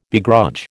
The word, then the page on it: begrudge: American English